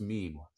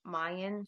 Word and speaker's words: mien: Yeah, it's pronounced mean. And I didn't know that until just now. I went with pronunciation in her reading earlier, which was, what did you say? What did you say? – I said, how'd you pronounce it? – I pronounce it like Mayan, like mine.